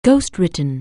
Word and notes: ghostwritten: anglais

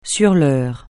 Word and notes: sur l'heure: French

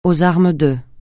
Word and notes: aux armes de: francais

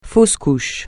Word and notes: fausse couche: francais

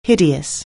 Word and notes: hideous: anglais

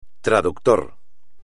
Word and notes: traductor: espagnol

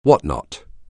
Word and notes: whatnot: anglais

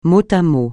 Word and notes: mot à mot: francais

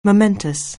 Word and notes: momentous: anglais

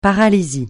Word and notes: paralysie: francais

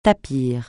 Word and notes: tapir: francais

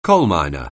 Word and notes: coalminer: anglais

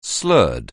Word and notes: slur: anglais

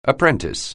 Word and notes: apprentice: anglais

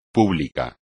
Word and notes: público: espagnol

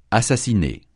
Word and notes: assassiner: francais

